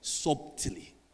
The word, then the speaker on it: subtly: You don't pronounce it like that, but let me subtly. Subtly, because the B is silent.